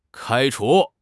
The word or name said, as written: 开除